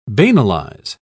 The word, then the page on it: banalize: British English